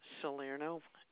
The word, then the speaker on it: Salerno: It's probably under Joseph Salerno, S-A-L-E-R-N-O. – That was S-A-L-E-R-N-O? – Yes.